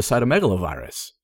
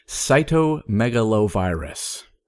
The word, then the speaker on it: cytomegalovirus: CMV is a cytomegalovirus.